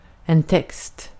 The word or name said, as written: text